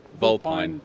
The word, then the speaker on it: Vulpine: who started Vulpine. If I, am I saying it right? Um, Volpine. – Volpine.